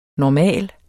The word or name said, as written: normal